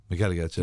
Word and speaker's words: McElligott: Then they have a really talented player in Jack McElligott, or McElligott. – McElligott, yeah. – You pronounce the G, you do.